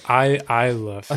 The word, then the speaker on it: Isla Fisher: But it's pronounced Isla Fisher.